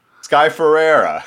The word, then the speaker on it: Ferreira: But anytime I mispronounced Sky Fiera, did I say it right that time? – No. – Well, how do you say it? – Sky Ferreira. – Sky Ferreira.